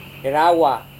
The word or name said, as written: rerawa